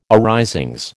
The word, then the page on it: arisings: American English